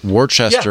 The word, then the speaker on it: Worcester: And that's exactly what happened in our next article that we're going to talk about, which comes to us from Worcester. Am I saying that right? Worcester, yeah. Massachusetts.